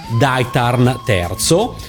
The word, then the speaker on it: Daitarn III: Nella videosigla finale storica della serie, invece, il titolo della sigla è Daitarn III, eh, come il titolo della serie, quindi con una R che poi nessuno pronuncia.